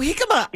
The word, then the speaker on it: jicama: Yes, and so I have to tell everybody, you spell that J-I-C-A-M-A. – Yes, it starts with a J. – So you say jicama.